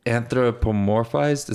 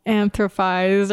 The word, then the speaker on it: anthropomorphized: Like anthropized, or like you skipped the middle syllable somewhere. Something was off. I just remember that. – Okay. – Because it is anthropomorphized.